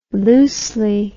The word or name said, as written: loosely